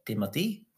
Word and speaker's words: Timothée: It's like Timothy Chalamet. It's like spelled weird. His name is spelled like T-I-M-O T-H-E-E or something, like Timothy.